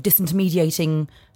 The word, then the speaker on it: disintermediating: Or is there a danger that this it will then start sort of disintermediating? Did I say that correctly? Disintermediation. – You did, yes.